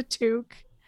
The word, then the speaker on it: toque: And how did you pronounce this word for the thing you put on your head? – It's a toque. I'm Canadian.